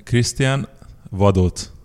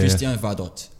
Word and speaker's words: Krisztián Vadócz: Yeah, Krisztián Vadócz. – Yeah, yeah. But almost in Hong Kong, everybody's pronounced like Vadossi. So it's yeah, really close, really close.